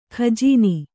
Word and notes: ಗಜನಿ: kannada

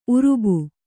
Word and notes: ಊರುಬ್ಬೆ: Kannada

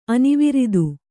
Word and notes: ಅನಾವರ್ತ: Kannada

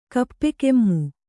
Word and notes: ಕಪ್ಪೆಕೆಮ್ಮು: kappekemmu

